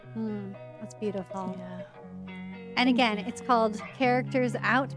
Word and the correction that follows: Character Outs: Podcast. Am I saying that right? – Character Outs. – Character Outs. – Character I switched the outs.